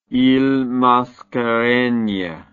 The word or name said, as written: iles mascareignes